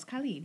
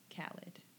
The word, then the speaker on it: Khalid: He actually puts how you pronounce it in his video, and I'm just like, it's Khalid, yeah. – Okay. – It's not Khalid.